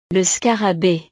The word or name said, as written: le scarabee